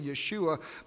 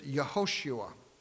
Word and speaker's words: Yehoshua: I keep saying Yeshua, but I understand the proper pronunciation is Yehoshua.